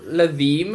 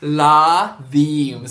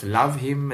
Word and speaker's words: Lathim: So Lathim, I know a lot of people have trouble saying my name. So when I do shows now, I generally make people say my name by syllable. Lathim. So I'll make them repeat it a couple of times, because I've gotten some crazy feedback. You know, it's love him, it's Lathim, it's Lucine, it's all kinds of stuff.